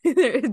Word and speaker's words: Dain: That's how you say his name? Dain? – Yes, so or Dain? – Dain. Dain. So, yes.